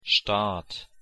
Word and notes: staat: German

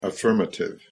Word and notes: affirmative: English

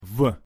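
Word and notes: в: Russian

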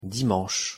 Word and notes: dimanche: French

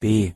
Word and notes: b: German

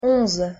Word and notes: onze: French

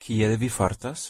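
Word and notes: kiel vi fartas: Esperanto

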